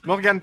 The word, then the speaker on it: Morgane: C'est Morgane ou Morgan? – Morgane. – Morgane, parce que j'ai un ami, il s'appelle Morgan et ça s'écrit comme toi et il aime pas qu'on dise Morgane.